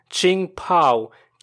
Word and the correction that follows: Jingpo: One of them was pronounced Jingpo. – Okay. – It's Jingpo.